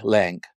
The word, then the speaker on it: Laing: Lang is spelled L-A-I-N-G, Lang.